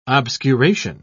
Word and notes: obscuration: British English

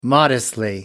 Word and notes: modestly: British English

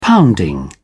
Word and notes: pounding: British English

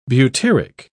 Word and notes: butyric: British English

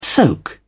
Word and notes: soak: British English

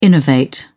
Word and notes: innovate: British English

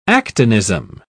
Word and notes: actinism: British English

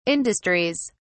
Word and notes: industries: British English

